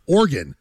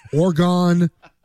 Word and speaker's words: Oregon: It's Oregon, not Oregon.